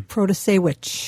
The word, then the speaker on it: Protasiewicz: Vote for Judge Janice Protasiewicz. Did I say that right? – Protasiewicz.